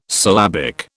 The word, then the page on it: syllabic: British English